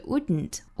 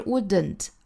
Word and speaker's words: wouldn't: 后面 you wouldn't feel, 这个 wouldn't, 我们是听不到里面的这个 d 的发音的，不是 wouldn't, 而是 wouldn't, wouldn't。